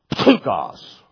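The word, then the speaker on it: Ptukos (πτωχός): And you are ptukos. That's how it's said. Ptukos. How do you say P-T-U? Ptu. Like you're spitting.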